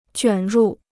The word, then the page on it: 卷入: juǎn rù